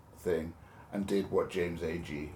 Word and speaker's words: Agee: James Agee. – Agee, okay. – Agee.